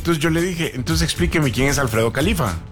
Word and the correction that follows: Jalife: Alfredo Jalif. – Ah, Jalif, eso. – Se escribe Jalife. – Jalife. – Jalif. – Sí, es Alfredo Jalif Ramé.